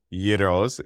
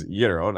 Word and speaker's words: gyro: I was told by a gentleman once that sold gyros is gyro, not gyro, but I got the gyro.